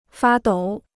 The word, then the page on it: 发抖: fā dǒu